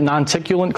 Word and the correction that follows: noctilucent: cloud, right? – Noctilucent. – Nocticulant cloud? Noctilucent. Yeah, yeah. I've mispronounced it like a thousand times.